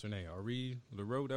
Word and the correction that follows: Ari Lennox: that what you said? What was it? – Oh, yeah, Ari Lennox, yeah.